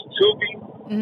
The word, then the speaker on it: Tubi: How do you say that? – It's Tubi, but actually I canceled those.